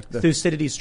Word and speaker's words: Thucydides: Thucydides trap. – Thucydides trap. – Thucydides.